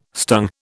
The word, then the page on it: stung: British English